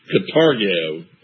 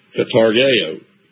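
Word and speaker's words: Katargeo (καταργέω): The word destroy is kategeo. And there's I want to read you the definition to kategeo there in verse 14. Katargeo. I'm sorry, I mispronounced it. Katargeo.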